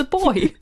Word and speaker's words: buoy: How do you think Americans pronounce that word? Uh, I don't know. Buoy. Buoy? Well, that's just wrong. It's a boy.